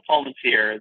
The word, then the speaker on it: Palmatier: you were close it's just Joshua Palmatier.